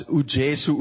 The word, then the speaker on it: uJesu: It's uJesu uyakuthanda in Zulu. In Xhosa, it's uYesu. So it's just the J that's pronounced differently.